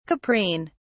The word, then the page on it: caprine: British English